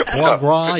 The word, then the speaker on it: foie gras: Faux gras, is that how you pronounce it? – Yeah, foie gras, yeah.